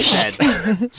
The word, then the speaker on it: Z: In the UK, the letter Z is pronounced how? – Spite. – Jim. – Spite. Zed. – Zed is correct.